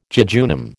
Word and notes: jejunum: American English

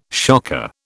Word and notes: shocker: British English

